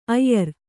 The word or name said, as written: ಆಯಾರ